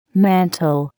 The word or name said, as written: mantel